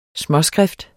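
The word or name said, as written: småskrift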